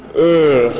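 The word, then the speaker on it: Eaarth: Someone asked me today how to pronounce it, and I said you kind of have to do a sort of channel Arnold Schwarzenegger. It's sort of Earth, you know, is the pronunciation.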